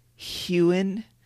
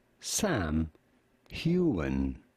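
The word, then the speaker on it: Heughan: It's Hewen.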